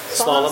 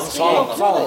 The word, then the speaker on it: Slalom: How do you say it? Home? – Slalom. – Slalom. Thank you.